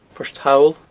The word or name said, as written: Port Thall